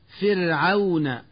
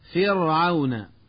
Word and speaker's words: فرعون: والآن مع الدرس الخامس ومع القراءة الصوتية الثانية والتي سأحاول من خلالها تنبيه المتعلم إلى الأخطاء النطقية الشائعة في الكلمات التالية: فرعون، ويقول من يخطئ فرعون بتفخيم الراء، فحشر،